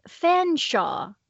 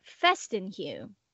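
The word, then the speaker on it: Featherstonehaugh: Because everything that Gail Carriger talks about, she says that this is like an actual name, and it's pronounced Fanshawe in your... – In my language. – Yes, in your language, Fanshawe. But the audiobook pronounces it Festenhugh, which, I mean, that's still missing several letters.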